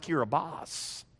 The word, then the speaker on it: Kiribati: A missionary to what we thought was Kiribati came through and explained to us that even though it had a T-I, it's not pronounced Kiribati, it's pronounced Kiribas. And apparently the story goes that whoever wrote back didn't have an S on his typewriter, and so he spelled it T-I instead of with an S.